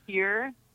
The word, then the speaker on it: Hir: It's called H-I-R. First of all, how is that pronounced? Her? Hire? – It's pronounced here. – Here. – Like, H-E-R-E, spelled H-I-R.